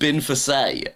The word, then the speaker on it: Binface: Binface is actually pronounced Binfase.